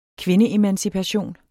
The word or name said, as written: kvindeemancipation